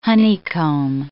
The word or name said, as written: honeycomb